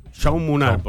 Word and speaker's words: Jaume: Jaume Munar, porque es catalán.